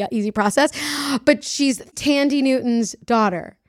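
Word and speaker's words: Thandie: spelled Thandy, but it's yeah. Tandy.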